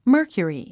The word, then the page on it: mercury: mə́ː r kjuri; マーキュリィ